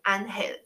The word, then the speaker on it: ángel: a a e a 什么的发音没有这种发音变化音，发音很简单，所以它就是 a 发 r，所以就是照着西文发音 ángel，只有两三个简单的变化，可是你很快就会，你掌握了，你就都会念了。